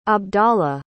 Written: abdalla

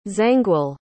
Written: Zangwill

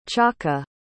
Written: chaka

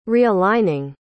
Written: realigning

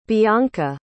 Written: Beonca

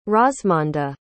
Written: rozmonda

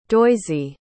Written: doisy

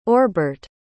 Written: orbart

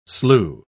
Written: slue

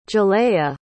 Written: ja'layah

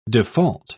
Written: default

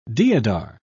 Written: deodar

